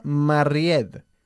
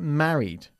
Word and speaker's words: married: Por cierto, no decimos nunca married. Se pronuncia married. – Married. – Married. – Married. – Eso es, enfatizando la primera sílaba.